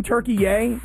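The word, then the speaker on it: Türkiye: Listen, I liked saying Turkey Yay because I found this interesting that there was this new pronunciation of the country Turkey.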